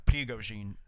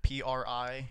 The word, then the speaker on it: Prigogine: Prigogine. Prigogine.